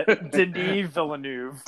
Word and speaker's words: Denis Villeneuve: Well, Denis Villeneuve came out and said Denis Villeneuve. I pronounced it wrong for so long. – Oh, really? – Yeah, Denis Villeneuve.